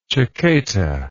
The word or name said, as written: chaqueta